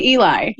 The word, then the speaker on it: Elison: Do you pronounce it Ellie? – I say Eli.